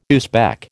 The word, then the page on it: Uzbek: American English